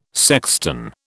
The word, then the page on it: sexton: British English